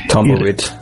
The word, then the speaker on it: Tumbleweed: Ya había tenido Tumbleweed, o Tumbleweed. – Tumbleweed.